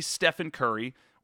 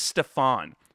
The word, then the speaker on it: Stephen: One of the first things they mention in the article is that in the pitch to Steph Curry, the pitch man mispronounces his name. He doesn't say Stephen Curry or Steph, he says Stefan, specifically Stefan, just like the Steve Urkel alter ego.